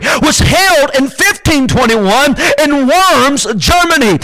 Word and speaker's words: Worms: And when you look the word up, it's actually pronounced Worms or something like that, but V is actually, you know, the German language is W, so it doesn't sound that weird if you're German.